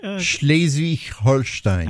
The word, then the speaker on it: Schleswig-Holstein: Schleswig-Holstein.